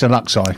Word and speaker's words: Alexi: And you can find her on Alexi. I never say it right. – Alexi. – Alexi. – Alexi. – I say it like a Liverpudlian. – Yeah, it's Alexi.